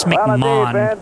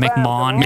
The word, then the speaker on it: McMahon: So now, that says Vince McMahon. That's how they say it in England, McMahon. – McMahon? – But now it's McMahon here, McMahon in England.